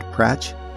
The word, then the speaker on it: Prach: You can follow Pratch on TikTok at Pratch on Earth. Pratch is spelled P-R-A-C-H.